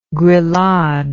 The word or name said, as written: grillade